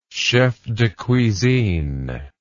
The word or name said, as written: chef de cuisine